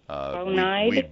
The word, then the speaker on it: Bonide: Bonide. – It's B-O-N-I-D-E, and it's Bonide. They got on me this year for mispronouncing their company name.